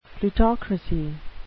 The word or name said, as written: plutocracy